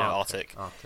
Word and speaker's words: Arctic: Yeah, I mean, I suppose the C can get lost if you say it quite quickly. You know, Arctic. Some people probably think that's how it's pronounced and therefore spell it that way.